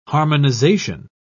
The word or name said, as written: harmonization